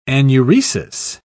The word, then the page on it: anuresis: British English